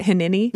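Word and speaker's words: Hineni (הנני): Is that how you say it? Hineni? – Mm-hmm.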